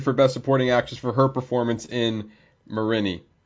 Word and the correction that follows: Minari: Did I say that right? Damn it. I always go back to it. Minari.